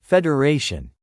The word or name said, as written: Federation